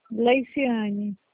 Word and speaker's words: Leiciane: por gentileza, falo com a Gessiane? – Com quem? – Gessiane? – Leiciane. – Isso. Ai, perdão pela pronúncia.